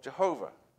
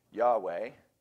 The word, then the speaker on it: Yahweh: And this gave rise to the mistaken idea that the name of God was pronounced Yahovah or Jehovah. Today, scholars agree that a much more likely original pronunciation of God's name is Yahweh but that's still mostly a guess.